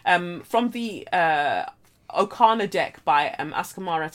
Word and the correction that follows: Akamara: Tarot, or is it Akamara Tarot? I think it's Askamara because I'm always thinking about how I pronounce this poor woman's. Um, oh, it's Akamara. I've been saying all this time. I've been saying Askamara.